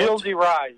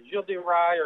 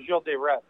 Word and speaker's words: Gilles de Rais: Gilles de Rais. Gilles de Rais or Gilles de Retz.